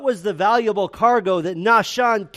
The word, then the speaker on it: Nahshon: In Hebrew, you would say it Nachshon. We would say Nahshon if we just pronounce it in English.